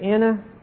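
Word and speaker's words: anakainosis: Renewing is the word anakinosis. Anakinosis. It's a very important word in Paul's letters particularly. He is the one who really emphasizes this concept. A-N-A-K-A-I-N-O, long O-S-I-S.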